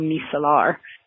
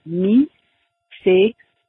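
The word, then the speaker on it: micellaire: And then the second word, most people in French pronounce it mi-c-l-air. Although I have heard lots of people in English say "eau micellar," but technically in French it would be micellar.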